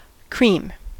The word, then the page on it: cream: American English